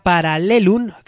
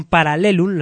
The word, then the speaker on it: Paralelum: Una banda que nos llega desde Madrid, ya lo pinchamos en su día, vamos a pinchar nuevamente a Paralelum, que quede bien pronunciado, no confundir con nuestros Parabellum. Estos son Paralelum, las dos con L.